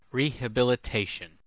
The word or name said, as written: rehabilitation